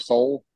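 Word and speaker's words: Sol: Have you guys heard of Soul? Is that how you pronounce the beer? – Yeah.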